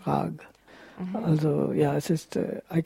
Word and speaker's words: raga: In Hindi you write raga, but you do not pronounce the vowel, so you say rag. It's the classical pronunciation, raga, tala, but in the Umgangssprache it's rag and tal.